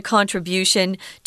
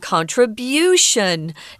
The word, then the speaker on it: contribution: You're making a contribution to improve things. Uh, notice here that we're saying contribution, not contribution, mm. um, which is a, uh, a, different pronunciation that you'll hear sometimes by British speakers.